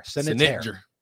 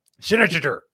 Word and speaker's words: Senetjer: And that's how you pronounce it. S-E-N-E-T-J-E-R. Sénétaire. Sénétaire.